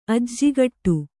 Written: ಅಜ್ಜಿಗಿಡ

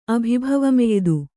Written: ಅಭಿಭವಮೆಯ್ದು

